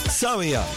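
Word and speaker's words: Xàbia: ¿Cómo se pronuncia todo? Sabia con X. Sabia.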